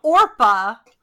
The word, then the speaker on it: Orpah: Orpa.